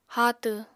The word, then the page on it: haatw: Apatani